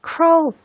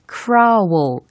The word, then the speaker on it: crawl: crawl 요 발음이 어, 많은 분들이 잘못 발음을 하는 경우가 되게 많더라고요. – crawl. – 특히 크라워 이런 아, 식으로. 아닙니다. – 아닙니다. 아닙니다. 아닙니다. 어떻게 해야 됩니까? 아 그냥 crawl. crawl. crawl. 아주 심플. 그죠?